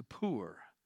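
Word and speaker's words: Pur: the poor is how it's pronounced, that is the lot, for their ruin and destruction.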